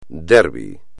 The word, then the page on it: derby: espagnol